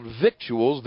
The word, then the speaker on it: victuals: It's spelled victuals, V-I-C-T-U-A-L-S, but like the Beverly Hillbillies, it's pronounced victuals.